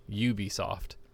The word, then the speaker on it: Ubisoft: I think I've mentioned it before, but now that I don't work at that retail chain anymore, he was Ubisoft, however you want to pronounce it. No, Ubisoft. – Ubisoft.